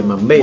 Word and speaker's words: Mambéty: ¿Es Mambeti o Mambeti Mambeti Ah, Mambetti. acento en la E, Mambetti. Muy bien.